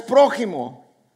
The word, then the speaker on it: prójimo: La palabra es prójimo, pero la J también se puede pronunciar como X. Así como México se escribe con X, pero se pronuncia con J. Así prójimo se escribe con J, pero se puede pronunciar con X.